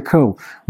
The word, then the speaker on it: Ko: I'm your host, Pastor Victor Ko.